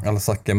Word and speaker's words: Alsace: Eh, detta var ju då eh, i Alazage, eller Alazac. Jag är så dålig på att uttala franska namn alltså. Alsace, men det är nu i alla fall i moderna Frankrike det ligger i.